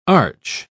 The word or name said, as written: arch